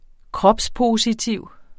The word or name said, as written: kropspositiv